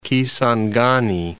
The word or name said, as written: Kisangani